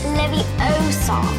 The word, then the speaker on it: Leviosa: You're saying it wrong. – It's Leviosa.